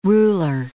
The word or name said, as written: ruler